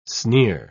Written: sneer

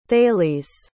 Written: thales

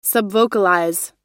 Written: subvocalize

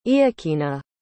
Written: iokina

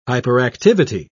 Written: hyperactivity

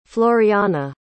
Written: floriana

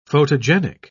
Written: photogenic

